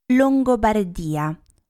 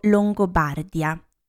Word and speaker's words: Longobardia: Il nome della Lombardia deriva da Longobardia o Longobardia, ho trovato entrambe le pronunce, che indicava la zona di Italia sotto il dominio dei Longobardi, che erano una popolazione germanica.